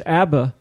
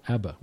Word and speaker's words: ABBA: We pronounce the initials as a word itself. Yes, ABBA. We don't say A-B-B-B-A. That'd be too many B's, but A-B-B-A. We actually say ABBA, but we don't say FABI for FBI.